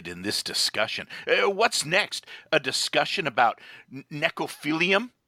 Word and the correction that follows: necrophilia: I think it's pronounced necrophilia, and why does it seem only baby boomers are into that stupid swinger scene?